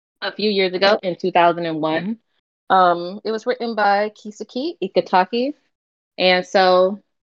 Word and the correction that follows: Itagaki: Well, no, I said that wrong. Itagaki, my bad.